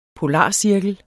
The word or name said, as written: polarcirkel